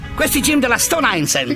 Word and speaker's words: Stone Island: Questi gym della Stone Islands. – Stone Island. – Ma perché scrivono? Scrivono come si legge. – Si chiama così, Stone Island.